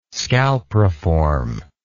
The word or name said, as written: scalpriform